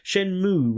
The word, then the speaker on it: Shenmue: Shenmue, with a soft moo on the end, is apparently the acceptable pronunciation, just if anyone's in doubt, but Shenmue will ever be the way that we pronounce it.